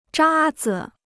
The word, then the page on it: 渣子: zhā zi